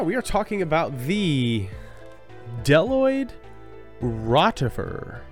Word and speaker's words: Bdelloid: I'm, there's a B at the beginning of that first word, Bdelloid. – The yeah. Bdelloid. – Um, it's not pronounced. um, I would imagine it isn't. – Because it's impossible. It's not a buzeloid.